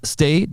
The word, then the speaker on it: Stage: Füllkrug, Duxch, Bittencourt, Weiser, der Außenverteidiger, der ja eher so einen offensiven Außenverteidiger gibt oder auch Jens Stay, der zwar Stage geschrieben wird, aber Stay wie to stay, bleiben, englisch ausgesprochen mit Stage, die waren immer da und haben die Wolfsburger so unter Druck gesetzt.